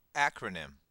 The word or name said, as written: acronym